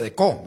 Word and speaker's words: Decaux: Hay una marca que se llama Decaux, uh-huh, que se pronuncia Deco, ¿verdad? Es sí, francés.